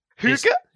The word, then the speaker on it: hygge: Higa. – I think... Hygge? – Hygge? – Yeah, Hygge. – Ishta Hagda Hygge. – Like, I've heard some people pronounce it hug, some people pronounce it hugger.